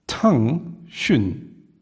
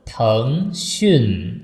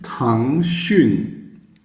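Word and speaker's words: Tang Xun (腾讯): Also ich sehe, die Geste ist richtig, aber die Aussprache kann man noch ein bisschen verbessern. – Schon wieder? – Also Tang Xun. Einmal hoch, einmal unten. – Tang Xun. – Genau.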